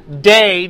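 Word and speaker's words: dei (δεῖ): This word ought is the word that's pronounced dei, D-E-I.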